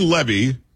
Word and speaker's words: Levy: So Dan Levy, because it was Eugene Levy's son. That's how they pronounce it.